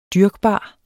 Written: dyrkbar

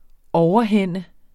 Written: overhænde

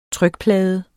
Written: trykplade

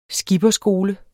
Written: skipperskole

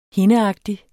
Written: hindeagtig

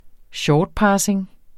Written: shortpassing

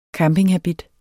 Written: campinghabit

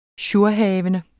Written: jourhavende

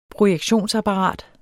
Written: projektionsapparat

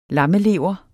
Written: lammelever

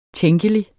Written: tænkelig

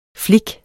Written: flik

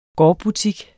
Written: gårdbutik

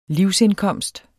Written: livsindkomst

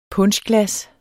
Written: puncheglas

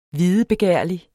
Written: videbegærlig

